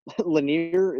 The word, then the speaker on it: Lanier: First pick, Bob Lanier.